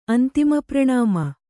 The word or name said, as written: ಅಂತಿಮಪ್ರಣಾಮ